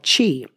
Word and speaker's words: qi: It's called qi, and it's spelled Q-I.